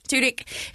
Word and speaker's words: Tudyk: Tudyk.